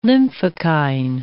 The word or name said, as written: lymphokine